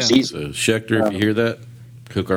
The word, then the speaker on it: Schecter: I like saying Schecter. Good. It just rolls off the pretty good. – It rhymes with sphincter. That's why you like it. – Schecter, yeah, sphincter. Yeah, it does.